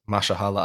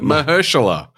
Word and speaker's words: Mahershala: Mashallah Ali. – Mah- I get that right? Mahershala.